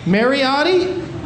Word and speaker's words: Mariotti: And so that's in the bag tonight with a note that says the book is signed by CEO of Funko, Brian Masciotti. Is that how you say it? – Mariotti? – Oh, that looks like an S, dude.